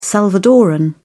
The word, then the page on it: salvadorean: British English